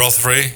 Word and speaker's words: Rothery: Rothery, ja.